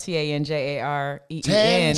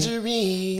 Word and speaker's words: Tanjareen: It's spelled T A N J A R E E N. Tanjareen. Because that is the correct way to pronounce my name. The E's. Tanjareen.